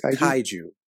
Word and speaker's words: kaiju: Pretty much every episode, there's action with a gaiju. Yeah. I'm saying it right, right? Am I saying it right? – It's kaiju. – Kaiju. Yeah.